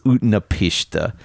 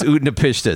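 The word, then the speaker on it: Utnapishtim: I think it's pronounced Utnapishtha in Gilgamesh, but it's the same.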